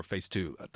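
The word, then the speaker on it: PHSEII: And it's a local band we will listen to, a duo called Phase 2, P-H-S-E-I-I, or Phase 2. That's how you pronounce it.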